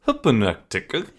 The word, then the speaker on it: Hypnotiq: And let's talk about how it's spelled, okay? Hypnotiq. You're like ordering, you're like, can I get some Hypnotic? And they're like, what? And you're like, Hypnotic.